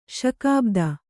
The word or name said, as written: ಶಕಾಬ್ದ